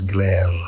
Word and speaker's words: Gleyre: It's a guy called Glayre, G-L-E-Y-R-E. Or probably if I pronounce it properly, I would roll the R's, Glayre.